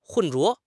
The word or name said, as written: 混浊